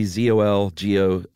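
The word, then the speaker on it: Czolgosz: I would say Zolgos. – That's pretty good. Gozar. – Silent C, Zolgosz.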